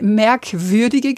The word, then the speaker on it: merkwürdige: Es wird eine merkwürdige Geschichte, ja, Betonung auf würdig, das sage ich schon gleich.